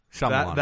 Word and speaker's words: Shyamalan: Shyamalan. – That is the correct way to pronounce it.